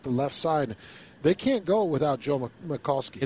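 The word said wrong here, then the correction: Michalski: It's actually Mahalski. Forgive the mispronunciation. Mahalski remains down on his knees as they go to a timeout on the field, so we'll take it.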